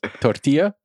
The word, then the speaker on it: tortilla: Can you pronounce it as if you were a native Spanish speaker? – No. – Do it. – Tortilla? – Yes.